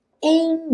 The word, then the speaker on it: Ain't: Ain't No Sunshine. Esse T do ain't some e a gente liga os dois N's.